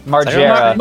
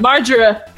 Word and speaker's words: Margera: Bam Margera? – Margera. – Margera.